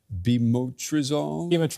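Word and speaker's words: Bemotrizinol: Is it Bimetrazenol.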